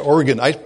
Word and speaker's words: Oregon: It was a fantastic conference, by the way, in Oregon. That's how you pronounce that state, in case anyone was wondering. That's everyone out there, Oregon.